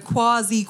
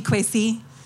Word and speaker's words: Kwasi: So Kwasi Kwarteng, it's meant to be Kwesi.